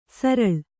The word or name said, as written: ಸರಲ್